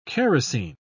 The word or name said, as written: kerosene